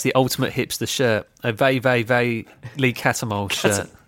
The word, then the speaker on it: VVV: They're called Vey. Yeah, that's how you pronounce it. Obviously, to us, it's just VVV, but in Dutch, it's Vey.